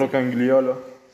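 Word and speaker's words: Roncagliolo: También prohibieron un libro que la verdad no sé cómo se pronuncia el nombre del escritor, es Santiago Roncañolo, me parece. – Roncañolo, algo así. – Roncañolo. se escribe rocañolo, supongo que se pronuncia. – Rocangliolo.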